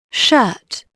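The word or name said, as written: shirt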